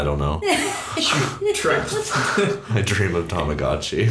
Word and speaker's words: Tamagotchi: Is it Tamagotchi? – Tamagotchi.